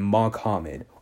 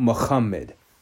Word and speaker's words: Muhammad: People are calling him Mohammed or Muhammad, not Muhammad, like it should be pronounced.